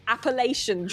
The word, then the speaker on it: Appalachian: And I'm really pleased that I managed to pronounce this trail correctly. The Appalachian Trail is what it's based around.